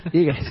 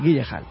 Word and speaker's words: Gyllenhaal: Guillehal, ¿verdad? – Guillehal. – Sí, sí, Guillehal, vale.